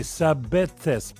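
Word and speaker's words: Sabethes: Вот мы сегодня слушали Дорс Москито существует прекрасный москито, прекрасный э, москит по имени Сабетхес, пишется S-A-B-E-T-H-E-S, а Сабетхес, Сабетхес, у которого, я посмотрел фотографию, просто никогда не видел, такие на ножках, как перышки.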